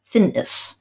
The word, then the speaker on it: Finnis: I just want to spell that for our listeners: F-I-N-F-I-N-N-I-S, Finnis.